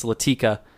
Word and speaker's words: Latika: You said you wish you would have got more of, it's not Lakita, it's Latika. Probably pronouncing that terribly.